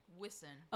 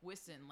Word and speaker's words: Wisen: So, but people get it wrong now, but they, I just tell them it rhymes with dissin or listen. – Wisen. – Pissin. – Okay, Wisen. – Wisen, like is listen.